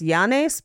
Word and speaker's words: Llanes: The orphanage set itself was an old colonial house in the town of, I believe it is pronounced Llanes, because it's L-L-A-N-E-S.